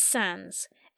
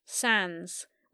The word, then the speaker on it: Sandys: It's Sands. S-A-N-D-Y-S. Pronounced Sands. With a silent Y.